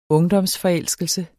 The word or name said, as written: ungdomsforelskelse